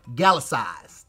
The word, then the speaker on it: Gallicized: G-A-L-L-I-C-I-Z-E-D. Gallicized.